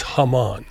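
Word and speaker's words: jamón: But it's spelled J-A-M-O-N, but it's Haman.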